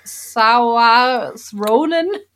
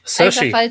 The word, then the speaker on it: Saoirse Ronan: Saoirse Ronan. – Saoirse.